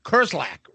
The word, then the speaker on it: Kerslake: And then also they get, I think his name is pronounced Lee Kerslake, right? Or Kerslake. – Yeah. Yep, Kerslake. Okay. Yep.